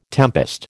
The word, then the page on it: tempest: American English